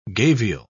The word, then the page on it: gavial: British English